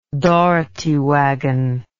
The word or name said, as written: dougherty wagon